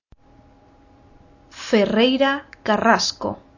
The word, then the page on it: Ferreira Carrasco: Spanish